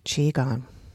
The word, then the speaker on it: qigong: I do know now that it is pronounced qigong.